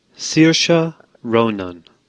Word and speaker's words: Saoirse Ronan: Saoirse Ronan.